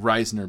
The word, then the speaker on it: Risner: And I'm pronouncing it Risner because that's what it's supposed to be.